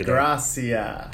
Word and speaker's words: DeGrassi: Did I say your name right? Or I fucked DeGrassi. – That's DeGrassi.